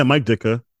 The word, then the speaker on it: Mike Ditka: Is him and I could be butchering his name, Mike Ditka? – Yeah, Mike Ditka. – Yeah, he plays his neighbor. That's all I remember. – A very shout out to Mike Ditka.